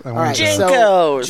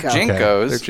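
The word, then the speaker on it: JNCO: How do you pronounce the name of the big pants? – Junkos. – They're Junkos.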